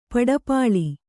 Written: ಪಡಪಾಳಿ

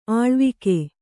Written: ಆಳ್ವಿಕೆ

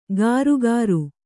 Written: ಗಾರುಗಾರು

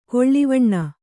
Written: ಗಿಳಿವಣ್ಣ

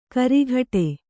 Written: ಕರ್ಗುಡಿ

